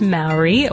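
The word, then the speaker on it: Māori: And we pronounced it Maori throughout the podcast, so we have to apologize for that and correct it. It is pronounced Maori, or at least that's an acceptable pronunciation, I think, for Yanks like us.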